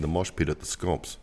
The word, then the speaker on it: Scamps: The other thing we learned is that one of her fellow teals, Sophie Scamps, it's not pronounced scamps. It's pronounced scomps, which sounds like the best ska punk band in the history of music.